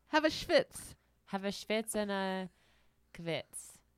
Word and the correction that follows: kibitz: Is that how you say it? Kibitz. Kibitz.